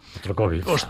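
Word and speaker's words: Shostakovich: Se llama The Second Vals, que es el segundo vals, y concretamente es de Dimitri Bostokovic. No sé si lo he dicho bien, creo que sí. – Bostokovic. – Exacto.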